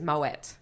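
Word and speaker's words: Moët: correct pronunciation is Moet. – Moet. – What? Even though that sounds dodgy, I'm quite sure that I have Googled this many times and Moet is the correct.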